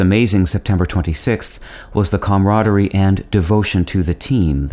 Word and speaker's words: camaraderie: The French pronunciation of camaraderie is camaraderie.